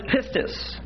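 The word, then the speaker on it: pistis (πίστις): The noun is pistis. In the Greek, P-I-S-T-I-S. But to believe is pisteuo. P-I-S-T-E-U, long O.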